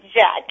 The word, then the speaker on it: JAK: So, before we go any further, tell us about this studio collection, J-A-K. How do you say it, and what is it? – You say Jack. It's Jack.